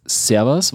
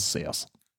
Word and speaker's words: Servus: Beziehungsweise das, die richtige Aussprache ist nicht Servus, sondern Servus.